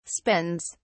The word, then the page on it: spends: British English